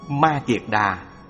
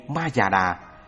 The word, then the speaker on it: Ma Kiệt Đà: Ma Kiệt Đà, phiên âm từ Phạn ngữ. Ma Kha Đờ Ha cũng đọc là Kiệt Xà hay Ma Già Đà,